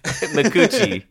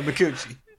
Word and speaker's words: Micucci: Micucci. – Yeah, Micucci.